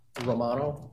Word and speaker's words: Romano: Scott Romano, R-O-M-A-N-O.